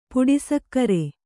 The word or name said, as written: ಪುಡಿಸಕ್ಕರೆ